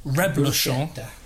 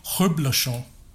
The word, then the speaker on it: reblochon: So the word is, you said reblochon. But it's actually reblochon.